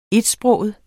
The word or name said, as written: etsproget